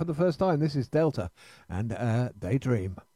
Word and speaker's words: D3LTA: That's spelled D3LTA, but pronounced Delta.